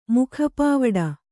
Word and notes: ಮುಖಪಾವುಡ: mukha pāvuḍa

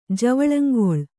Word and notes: ಜವಳಂಗೊಳ್: Kannada

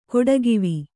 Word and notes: ಕೊಡಗಿವಿ: Kannada

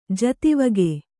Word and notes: ಜತಿವಗೆ: kannada

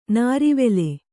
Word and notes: ನಾರಿವೆಲೆ: nārivele

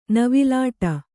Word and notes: ನವಿಲಾಟ: navilāṭa

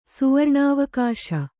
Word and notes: ಸುವರ್ಣಾವಕಾಶ: Kannada

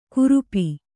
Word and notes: ಕುರುಪಿ: kurupi

